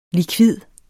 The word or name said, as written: likvid